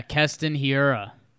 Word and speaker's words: Keston Hiura: Yelich home run to tie it, and then our dude, how do you say his name? Kenton Herrero? That's campy, right? – Yeah, Keston Hiura.